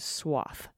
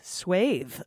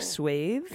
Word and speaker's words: swathe: The offending expression is swath. My admittedly petty bugbear is the pronunciation. You Americans, bless you, pronounce it as in moth, when in the original Anglo-Saxon it's completely different. Over here we pronounce it as in bathe.